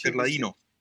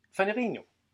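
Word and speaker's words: Ferlaino: Og der kommer der en, øh, en ret markant præsident, Faliano, tror jeg han udtales. Øh, du må gerne korrekt mig. – Fagliano. – Fagliano. Fint.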